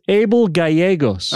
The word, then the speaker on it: Abel Gallegos: How do you pronounce that Gallegos. – Name? – It's Abel Gallegos.